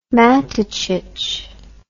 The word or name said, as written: Matacic